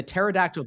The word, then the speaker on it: Pterodactyl: and the Pterodactyl Podcast, the Silent T. Silent P. Silent P.